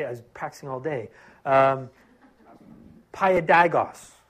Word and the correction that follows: paidagogos: I didn't say that right. Paiodagos. Uh, spell it this way P A I D A G O G O S with a funny O. Right? I can spell it, I just can't say it, which means a lot for me because I normally can't even spell. So, Paidagagos, that's what it is.